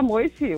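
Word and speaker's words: Moissi: Alexander Moisi oder so irgendwie. – Ja, Alexander Moisi, kann man Moisi auch aussprechen.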